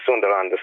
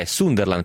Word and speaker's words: Sunderland: Sunderland, perché mi scrivono adesso, è praticamente in Scozia e la U si pronuncia U e non A, e la S è quasi una Z, mi scrive Francesco.